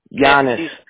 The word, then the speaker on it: Llanes: Giannis. K-A-T-H-L-E-E-N. Last name is spelled L-L-A-N-E-S.